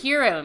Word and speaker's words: gyro: Are, so there's a lot of different pronunciations of this word, gyro meat or yes. Hero meat.